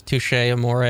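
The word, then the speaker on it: Touché Amoré: I always thought it rhymed, like, so it was Touche Amore, you know? – That sounds right.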